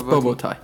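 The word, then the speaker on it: bobotie: Let's pronounce that boboti. – No, it's bobotai. – Bobo. I like bobotai. – Everything, like Boba Fett.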